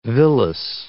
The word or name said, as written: villus